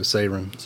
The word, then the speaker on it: Seiran: Yeah, well, the EI is the A sound, so Siren. – So Siren, yeah.